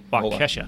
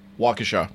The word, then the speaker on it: Waukesha: Waukesha. W-A-U-Kesha. – Waukesha. – Waukesha. I like the way I said it better. – Okay. – Anyway, the Waukesha